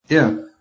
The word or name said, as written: IF